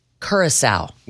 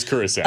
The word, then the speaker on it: Curaçao: It is Curaçao.